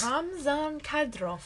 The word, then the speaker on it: Ramzan Kadyrov: Ramzan Kadyrov.